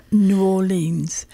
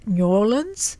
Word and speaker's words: New Orleans: So the premise is New Orleans, and it's New Orleans. I have been just advised a couple of weeks ago I was there. But if you're writing a song or poetry, you can pronounce New Orleans.